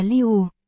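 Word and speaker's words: liu (六): Bên tai tôi, trong tiếng Trung, 6 đọc là liu, khá giống với cách phát âm của bò là niu.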